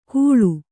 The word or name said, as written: ಘುಳು